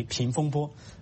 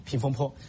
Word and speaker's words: 风波: 然后在习近平的讲话中跟胡锦涛的讲话不一样，胡锦涛纪念三十周年的时候对八九六四事件只字不提，然后习近平提到了，他说是应变局平风波，只是把坡读成了那个波读成了坡，平风波平风坡。